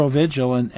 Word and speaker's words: Provigil: Provigil, is that how it's pronounced? – Mm-hmm. Mm-hmm. – Provigil, and you know, in the meantime, they made something like $2 billion.